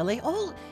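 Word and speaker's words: Laguiole: Now, Laol is, by the way, spelled L-A-G-U-I-O-L-E. I know you've seen it, and now you know how to pronounce it.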